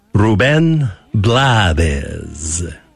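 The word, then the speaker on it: Rubén Blades: Can we say his name right in Spanish? Ruben Blades. So we'll Americanize it, say Ruben Blades.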